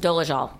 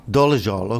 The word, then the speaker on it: Dolezal: Yeah, so how's it pronounced? – Dolezal. – Dolezal, okay.